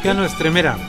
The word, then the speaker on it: Cano Estremera: Cano Herrera, Cano Herrera, ¿cómo se dice? – Cano Estremera. – Cano Estremera.